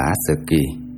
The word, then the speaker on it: ASCII: Ví dụ người Mỹ đã ban hành bảng mã ASC2, đọc là ASCII,